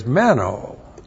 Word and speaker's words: meno: And the other word is meno. M-E-N long O.